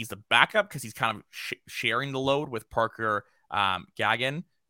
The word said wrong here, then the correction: Gahagan: Gahagan. Yeah. – H was silent.